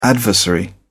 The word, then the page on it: adversary: British English